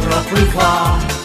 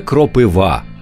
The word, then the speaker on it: кропива: Кропива моя кропива, це той випадок, коли Михайло Поплавський правий, і ця пісня принесла в маси правильний наголос: саме кропива, а не кропива, як часто калькують з російської.